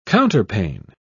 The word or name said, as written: counterpane